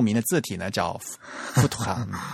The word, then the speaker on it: Futura: 是 Futura，富图哈，是德语应该念富图富图哈。